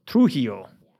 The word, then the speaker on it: Trujillo: Got that silent, that H sound or with the J. – What is Trujillo? Is it Trujillo? Trujillo.